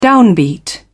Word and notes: downbeat: British English

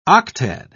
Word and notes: octad: British English

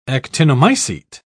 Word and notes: actinomycete: British English

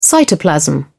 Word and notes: cytoplasm: British English

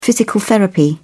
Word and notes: physical therapy: British English

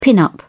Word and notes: pin-up: British English

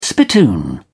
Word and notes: spittoon: British English

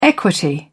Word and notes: equity: British English

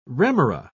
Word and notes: remora: British English

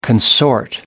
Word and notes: consort: American English

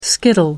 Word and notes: skittle: American English

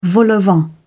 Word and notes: vol-au-vent: British English

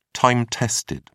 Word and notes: time-tested: British English

